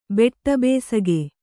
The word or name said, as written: ಬೆಟ್ಟಬೇಸಗೆ